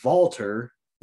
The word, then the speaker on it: Walter: I mean, granted, I'm pretty sure they've been saying his name wrong every time, no matter what, because it's Walter. – Yeah. – W is pronounced with a V in German.